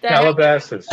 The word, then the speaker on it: Calabasas: Calabasas.